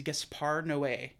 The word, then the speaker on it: Gaspar Noé: His name, and I had to look up the pronunciation of his name, is Gaspar Noé.